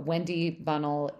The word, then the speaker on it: Wendy Bunnell: Or you can message me on Facebook or Instagram, Wendy Bunnell, or my name's on both.